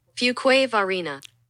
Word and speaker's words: Fuquay-Varina: Pronounce Fuquay-Varina. There is a thing. Okay. – Fuquay-Varina. – I was pretty close.